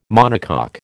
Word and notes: monocoque: American English

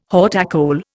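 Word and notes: haute école: Indian

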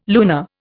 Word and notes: lunar: Indian

